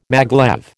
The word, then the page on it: maglev: American English